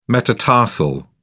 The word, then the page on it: metatarsal: British English